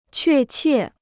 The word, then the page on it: 确切: què qiè